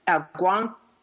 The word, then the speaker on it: Algonquin: But you stayed for 12 years with the Algonquin, am I saying that correctly? – Yes.